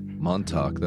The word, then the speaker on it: Montauk: Charlie Kessler, I love the music, who directed the short film Montauk? Is that how you pronounce that? – Montauk, that's what I go with.